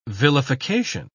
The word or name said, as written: vilification